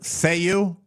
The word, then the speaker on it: Zayu: Zayu? – Zayu. – Zayu. How do you spell it? – Z-A-Y-U.